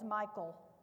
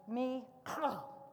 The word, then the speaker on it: Michal: I had always heard it as Michal. The Hebrew pronunciation is actually Michal. Did y'all get that?